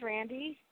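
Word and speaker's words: Randi: My name's Randy, and I'm a teacher.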